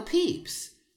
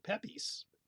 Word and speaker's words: Pepys: Right, it's P-E-P-Y-S. – Yeah. I heard about Samuel Pepys, which would obviously be spelled P-E-E-P-S, like the little, you know, soft Easter candy.